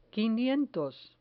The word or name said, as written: quinientos